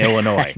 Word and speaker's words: Illinois: There's no noise in Illinois.